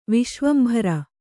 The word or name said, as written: ವಿಶ್ವಂಭರ